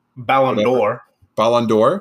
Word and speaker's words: Ballon d'Or: who Ballon d'Or, is that how you say it? Ballon d'Or, excuse me.